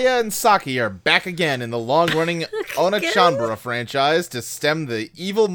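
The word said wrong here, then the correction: Onechanbara: It is pronounced Onichanbara.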